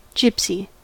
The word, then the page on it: gypsy: American English